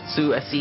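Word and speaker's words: SUSE: But we'll see where they're going to take Zeus, though. Is it Zeus? Zeus. – Su-S-E.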